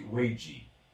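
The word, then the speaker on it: Weiji (危机): A palavra crise, em chinês tradicional, se escreve com os ideogramas da figura ao lado, pronunciando-se Weiji, estes ideogramas, de forma independente, representam as ideias de risco potencial e oportunidade escondida, respectivamente.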